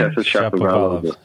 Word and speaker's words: Shapovalov: How do you pronounce his last name? – Shapovalov. Yeah. – Good Irish name. Well, Shapovalov. – Shapovalov is his name. The female version, Shapovalova.